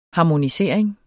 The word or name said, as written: harmonisering